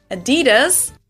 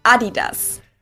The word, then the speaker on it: Adidas: they usually pronounce it Adidas. – Adidas? – And in Germany, we say Adidas. – Adidas? It's Adidas. Adidas. The emphasis is on the first syllable. Adidas. And there's no E, Americans.